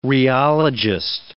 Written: rheologist

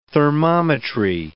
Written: thermometry

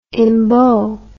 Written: embowment

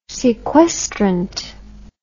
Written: sequestrant

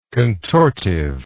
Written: contortive

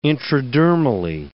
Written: intradermally

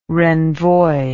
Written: renvoi

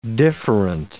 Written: different

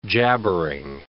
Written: jabbering

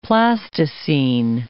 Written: plasticene

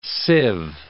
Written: sieve

